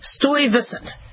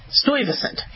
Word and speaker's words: Stuyvesant: Bedford Stuyvesant. Stuyvesant.